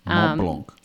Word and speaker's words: Mont Blanc: He got invited to hike Mont Blanc. Did I say that right? – France, yeah. Mont Blanc.